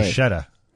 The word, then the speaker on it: bruschetta: B-R-U-S-C-H-E-T-T-A. – Bruschetta. – That is the incorrect way. – It is? – C-H is a K sound in Italian, so it would be bruschetta.